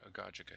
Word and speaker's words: agogic: It's and then um, agogic or agogic, I think, with a soft G, the second G.